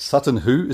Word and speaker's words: Sutton Hoo: Now, going to the last song, is it Sutton Hoo? Is that how you say it? – Yes, it is, yeah.